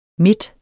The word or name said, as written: midt